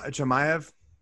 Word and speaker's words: Chimaev: Khamzat Chimaev.